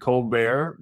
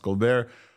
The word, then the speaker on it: ColBERT: I was surprised when Omar pronounced it ColBERT, because it was Bert and then there's Stephen ColBERT.